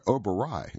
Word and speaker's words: Oberoi: Outfitters cruising the river include National Geographic Expeditions, Oberol, which is spelled O-B-E-R-O-L, or I'm sorry, Oberoi, excuse me, O-B-E-R-O-I, and Abercrombie and Kent.